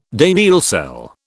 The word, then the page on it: Daniell cell: British English